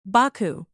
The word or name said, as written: Baku